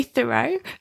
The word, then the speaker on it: Thoreau: But one of the philosophers, or a philosopher of sorts that you talk about in your book, Henry Thoreau, is that how you pronounce it? Henry Thoreau? – I tend to say Thoreau, but I've heard Americans pronouncing it the way that you are, so that might also be correct.